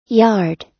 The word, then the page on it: yard: American English